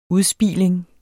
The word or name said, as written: udspiling